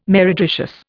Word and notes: meretricious: Indian

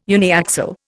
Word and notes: uniaxial: Indian

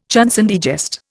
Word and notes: chanson de geste: Indian

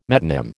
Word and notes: metonym: American English

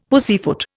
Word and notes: pussyfoot: Indian

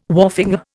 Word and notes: wharfinger: Indian

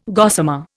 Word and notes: gossamer: Indian

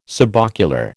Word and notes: subocular: American English

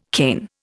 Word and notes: cane: Indian

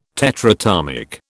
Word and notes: tetratomic: British English

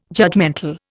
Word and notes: judgemental: Indian

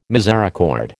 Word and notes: misericord: American English

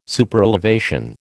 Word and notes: Superelevation: American English

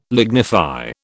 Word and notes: lignify: British English

